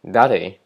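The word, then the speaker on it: 誰: 那日文的谁也是这样写，那它念作 “dare”。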